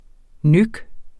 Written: nøk

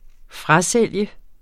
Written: FRASOLGT

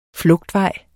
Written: flugtvej

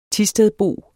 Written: THISTEDBO

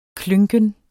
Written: klynken

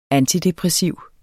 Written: antidepressiv

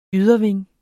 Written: yderwing

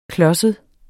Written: klodset

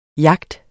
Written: YACHTERNE

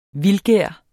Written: vildgær

